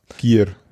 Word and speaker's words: Gier: Ja, Gear heißt das. – Gear, ja. – Gear. – Wie schreibt man das? – G-I-E-R.